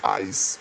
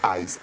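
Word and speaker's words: ijs: Helado es ice cream, que yo decir en holandés ij, pero parece que ser que es ice, porque la I, la J y la S se pronuncian ice, así como ahí, como que hay algo, pero en plural, ice, sí ice, cream, así como un cream.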